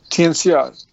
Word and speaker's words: Tianxia: And that is, the term for that is Tianxia, is that right? – Tianxia, T-I-A-N-X-I-A. – X-I-A, okay.